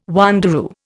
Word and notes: wanderoo: Indian

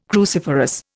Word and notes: cruciferous: Indian

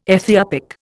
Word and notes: Ethiopic: Indian

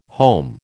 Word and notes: home: American English

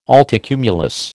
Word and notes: altocumulus: American English

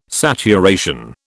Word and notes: saturation: British English

